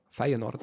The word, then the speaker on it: Feyenoord: Feyenoord.